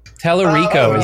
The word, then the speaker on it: Tallarico: Tallarico, is that how you pronounce it? – Yeah, Tallarico.